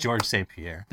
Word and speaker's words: Georges St. Pierre: Former UFC champ Georges St. Pierre, widely regarded as the MMA GOAT, uh, I'm probably pronouncing his name wrong. I don't. I, don't I think it's just George St. Pierre.